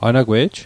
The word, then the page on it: anagwe'j: anakwe'j